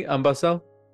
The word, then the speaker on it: Ambassel: Ambasel? – Yes, that's right.